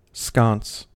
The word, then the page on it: sconce: American English